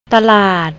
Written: talad

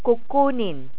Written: kukunin

